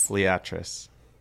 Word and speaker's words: Liatris: How do you say L-I-A-T-R-I-S? – Liatris.